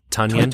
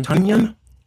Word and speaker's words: Tonyan: Tonyan. Tonyan. – You know, like Funyuns, but Tonyan. Tonyan. – Yeah, Tonyan with the T.